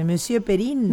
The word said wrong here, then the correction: Monsieur Periné: Monsieur. Monsieur Perin. O Periné. Periné. Monsieur Periné.